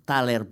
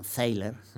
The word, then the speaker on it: Thaler: de Chicago, que es eh, Richard Thaler, bueno, Thaler pronunciado a la alemana, los americanos con su pronunciación peculiar lo llaman Thaler, llámelo usted como, quiera.